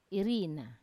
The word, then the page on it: irina: Wamesa